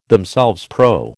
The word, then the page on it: themselves: American English